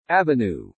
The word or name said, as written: avenue